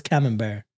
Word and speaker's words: Camembert: pronouncing it wrong too, but I'll, I heard it was camembert. So yeah, yeah, no, that is, yeah that's, that's, how, how it's pronounced.